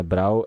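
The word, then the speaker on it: Bräu: Um ah, bom, achei que fosse B-R-E-W. – Não, é Brau, é Brau, se escreve Brau, mas ele tem o trema no A.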